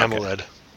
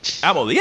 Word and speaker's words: AMOLED: How do you say mold? AMOLED? Is that how you Yeah. – Okay, so you don't like say it as one word. – No. Okay. AMOLED. – AMOLED, yeah, there it is.